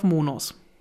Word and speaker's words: Monos: Ähm, Monos, ist das richtig ausgesprochen oder Monios? Ähm, einigen wir uns auf Monos.